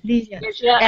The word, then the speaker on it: Lygia: How are you pronouncing her first name? Ligia. – Ligia. L-Y-G-I-A.